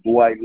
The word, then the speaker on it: Dwight: I was Dwight Lomax number two, you know, in the ID department.